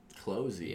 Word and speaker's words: Clozee: It's an artist, and she goes by the name of Closey. – Closey. – Yeah. I'm pretty sure I'm not mistaken on the pronunciation of her name. – It is spelled C-L-O-Z-E-E.